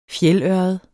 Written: fjeldørred

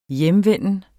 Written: hjemvenden

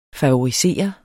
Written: favorisere

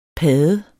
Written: padde

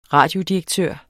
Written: radiodirektør

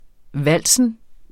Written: vals

